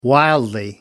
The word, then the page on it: wildly: American English